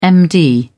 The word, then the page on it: md: British English